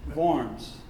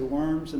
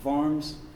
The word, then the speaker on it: Worms: And this is the site of Luther's famous Here I Stand speech as well, pronounced Worms. I want to say Worms, and I feel funny saying it, but I read last night Worms.